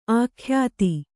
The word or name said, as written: ಅಖ್ಯಾತಿ